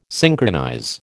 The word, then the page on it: synchronize: American English